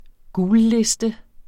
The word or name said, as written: gulliste